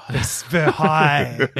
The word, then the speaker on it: Versailles: Versailles. That's how you say it in French.